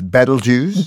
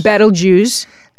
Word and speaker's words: Betelgeuse: More correctly, it's Betelgeuse. – Betelgeuse.